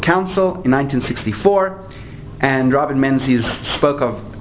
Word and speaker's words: Menzies: Am I pronouncing his name correctly? – Yeah, Menzies. Okay. See, in French, would have dropped the S.